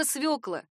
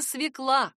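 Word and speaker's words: свёкла: Если поставить все точки над «ё» с ударением в словаре «свекла», все сразу станет ясно. Словари дают однозначный ответ. Только свекла. А в скобках строго предупреждают. Не свекла.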